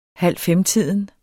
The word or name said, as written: halvfemtiden